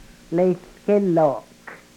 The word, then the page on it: lehlkelok: Yurok